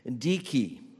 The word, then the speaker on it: Dike: It's capitalized because the translation of the Greek word translates the name of the Greek goddess, Dike. Is how it's pronounced. At least that's what YouTube told me.